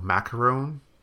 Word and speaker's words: macaron: There's the little macaron cookie or whatever it's called. – Macaron. – I can never say the name right. – No, because, you know, because we have a really similar cookie. We have macaroon cookies. – Yeah. – Which have coconut in them, which are also so delicious.